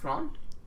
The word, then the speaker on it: Thrawn: Thrawn? – Yeah.